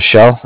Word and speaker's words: Schell: Shell, S-C-H-E-L-L.